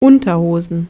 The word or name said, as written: azpiko galtza